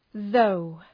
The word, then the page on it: though: ðəʋ